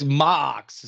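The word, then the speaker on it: Maax: It's like Max is how they say it, but it's like it's just Max with an extra A. Come on, guys. – Yeah, they put like two A's on it, but it's Max. John Amos, the great John Amos, playing Seth.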